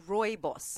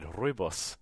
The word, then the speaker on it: rooibos: I think it's pronounced rooibos. – That's what I said, rooibos. You got to rule the R.